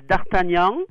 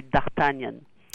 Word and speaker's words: D'Artagnan: Both ways. Both ways are good. The French way is D'Artagnan, but in America we say D'Artagnan.